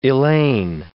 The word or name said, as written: Elaine